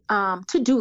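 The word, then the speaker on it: Teux Deux: Another personal habit that's helped me significantly, to-do lists. So there's, I actually use an app. It's called To Do, but it's spelled TEUX. DEUX.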